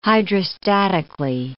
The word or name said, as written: hydrostatically